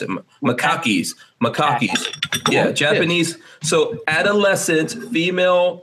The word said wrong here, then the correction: macaques: It's macaques.